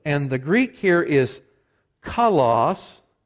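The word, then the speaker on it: kalos (καλός): Some in this room, maybe today, some certainly in our church, pronounce this word differently. Do you know how they pronounce it? They pronounce it kalos, and it's the place they're employed, and that's what that word means. That's not the way the Greeks pronounce it, but that's anglicized, that's what it means, virtuous, beautiful.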